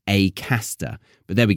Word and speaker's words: Acaster: My dialect would encourage me to say Acaster, but he is from the Midlands, and he pronounces his name with an A sound, not an R.